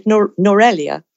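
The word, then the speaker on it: Nuwara Eliya: It's based on a town in the Hill Country called Norelia, but it's not spelt that way. It's spelt Nuara Elia.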